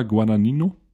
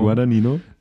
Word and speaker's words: Guadagnino: Der kommt nämlich demnächst raus und ist von Luca Guadagnino. – Mhm. – Siehst du? Richtig ausgesprochen. – Luca Guadagnino.